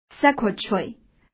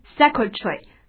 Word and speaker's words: secretary: Secretary. Nervous. Nervous. Afford. Afford. Weak. Weak. Interrupt. Interrupt. Well, first one is secretary. Secretary 名词，秘书的含义。好，这个词注意发音，既可以读作 secretary，或者读作 secretary 都可以， secretary 都可以。OK，好，秘书的含义，我们看一下，这个词词根是 secret。